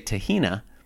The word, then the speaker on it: tahini: Well, look, okay, I've got a bit of a chip on my shoulder about the pronunciation of the word tahini. Sometimes I say tahina, which is what my Egyptian in-laws sometimes say.